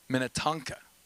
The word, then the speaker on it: Minnetonka: Minnetonka.